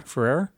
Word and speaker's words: Ferrer: So Miguel Ferrer, how do you pronounce his name? – Ferrer. – Miguel Ferrer.